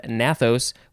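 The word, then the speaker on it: gnathos: Nathos, which means jaw. There's a G at the beginning of that word, by the way, nathos.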